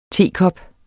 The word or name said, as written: tekop